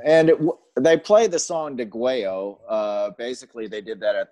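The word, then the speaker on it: Degüello: And so what I love about this album, okay, for starters, it's DeGuello. Is that how you say it? – I say DeGuello.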